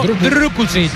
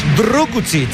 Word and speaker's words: Drukušić: Banja Drukušić, internacional esloveno. – Repítelo, repítelo. – Hay que pronunciarlo Drukušić. – Drukušić. – Drukušić. – Hay que poner la boca así como si.